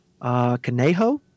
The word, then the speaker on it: Canejo: Canejo? – Yeah, that's exactly how you say it.